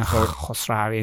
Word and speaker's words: Khosravi: You know, the first part, KH, we say Kha, you know, Khosravi. And then many people start chewing up after saying Kha. – So, Ali Khosravi.